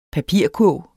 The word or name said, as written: papirkurv